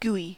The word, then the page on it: gooey: American English